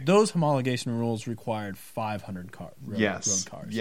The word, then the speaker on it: homologation: These are all homologation specials.